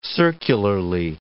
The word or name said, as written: circularly